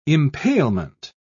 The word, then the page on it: impalement: British English